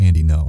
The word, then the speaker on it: Ngo: I don't know how to pronounce his last name because it's spelled N-G-O. He's Vietnamese, so that's not a weird non-governmental organization kind of thing. – Did he say? – I think it's pronounced Andy No. I'm just going to say Andy No.